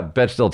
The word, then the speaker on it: Bechdel: And I don't know how to pronounce that. Bechdel test? – Yeah, Bechdel test, whatever.